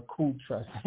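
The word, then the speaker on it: cestui que: Pronounced as if spelled Cest We Q Trust or Cool Trust. It's not even Q, it's Cool.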